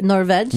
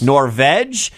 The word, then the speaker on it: Norge: N-O-R-G-E is pronounced... How do you say it? – Norveg. – Norveg.